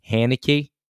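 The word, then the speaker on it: Heinicke: And Heineke. – Taylor Heineke. – I knew you were. You can't pronounce that. – Heineke.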